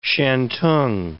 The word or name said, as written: shantung